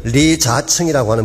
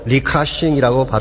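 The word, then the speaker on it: 리카싱이라고 (李嘉誠): 이 노신사 이름이 리자층이라고 하는 분입니다. 중국 발음으로 리카싱이라고 발음을 합니다.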